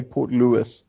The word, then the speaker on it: Port Louis: Well, it's spelled Port Louis, but the locals say Port Louis.